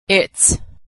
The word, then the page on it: its: ending s